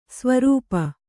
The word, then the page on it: ಸ್ವರೂಪ: svarūpa